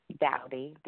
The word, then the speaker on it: Dowdy: Dowdy, D-O-W-D-Y.